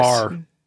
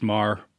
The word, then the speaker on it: Meagher: Oh, it says, Meagher County Undersheriff Jeremy West. – Mar. – Mar? – It's pronounced Mar. – You say that Mar? – Yeah. – Boy, I butchered that then. – Dude, I didn't even know. – Little Montana history, Thomas Mar. – Okay. – I live next to that street, and I was calling it Meagher for a long time.